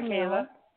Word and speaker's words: Kayla: Hi, Kayla.